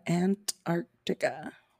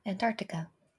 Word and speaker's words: Antarctica: How do you say Antarctica? Antarctica. – Yeah, Antarctica. – It's, I forget the arc in the middle.